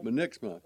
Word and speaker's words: Munniksma: Munniksma.